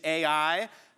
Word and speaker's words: Ai: You pronounced the city Ai. I pronounce it I. There are two correct pronunciations. We're both right.